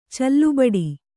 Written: ಚಲ್ಲುಬಡಿ

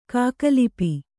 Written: ಖಗಲಿಪಿ

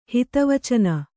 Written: ಹಿತವಚನ

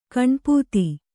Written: ಗಣಪದ

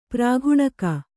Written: ಪ್ರಾಘುಣಕ

